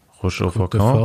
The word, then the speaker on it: Roche au Faucon: Roche au Faucon.